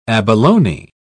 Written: abalone

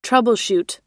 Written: troubleshoot

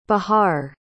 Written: Bihar

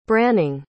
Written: branning

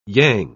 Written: yang

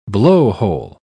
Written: blowhole

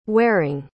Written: waring

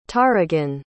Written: taregan

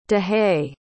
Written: dahy

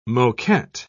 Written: moquette

